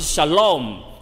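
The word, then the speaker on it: shalom (שלום): 撒冷王就是希伯来文的发音，撒冷就是 shalom，就是平安啦、平安就是 shalom 啦、所以撒冷王他把它翻出来嘛，后面叫做平安王，有没有？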